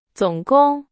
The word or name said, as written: 总共